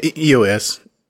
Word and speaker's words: EOS: Is that how you say it? EOS? – Yeah, EOS. Yeah.